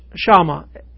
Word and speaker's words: Shama: It's pronounced Shama, S-H-A-M-A. And it means to hear intelligently, often with the implication of paying attention.